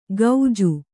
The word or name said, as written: ಗೌಜು